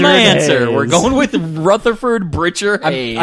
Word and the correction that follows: Birchard: I'm sorry, I mispronounced it. It's Beerchard. – Oh, Beerchard. – B-I-R-C-H-A-R-D.